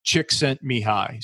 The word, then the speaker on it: Csikszentmihalyi: This is actually what his last name, how it's pronounced. Csikszentmihalyi.